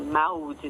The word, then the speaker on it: Maude: Então, eu tenho o meu Instagram, que é @mariamode, Mode se pronuncia, né, mas se escreve MAUD, então assim, Maude, né?